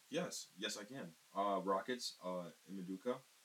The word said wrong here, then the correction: Ime Udoka: Say it right, right? – Ime Udoka. – Ime Udoka. I'm sorry about that.